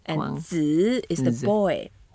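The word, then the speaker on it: Zi (子): Wang Wang And to pronounce Zi, we can put the upper teeth and lower teeth together. Mm.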